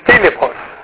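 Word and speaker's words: Filippos (Φίλιππος): So how do you pronounce it? Well, look where the accent mark is. Filippos.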